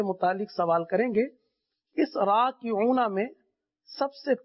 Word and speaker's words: راکعون: را الف زبر را کاف زیر کی را کی عین واو پیش راکعو نون زبرنا راکعون.